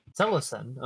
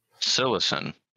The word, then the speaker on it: Psilocin: Excluding peyote, psilocybin, and what is that? How do you say that? – Psilocin. – Okay. – Psilocin.